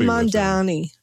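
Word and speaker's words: Mamdani: Mayor Mondandi, oh my God. Ma'am Donnie. – No, I think you said it right the first time. I got Zoran Mondani.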